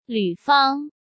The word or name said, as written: Lu Fang